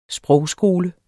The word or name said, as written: sprogskole